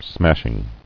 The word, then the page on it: Smashing: smash·ing